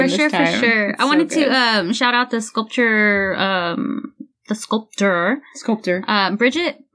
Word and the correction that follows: Brigitte: Mondrian? – Yes. – Is that how you pronounce it? – Yeah. Brigitte.